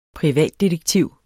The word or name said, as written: privatdetektiv